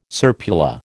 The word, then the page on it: serpula: American English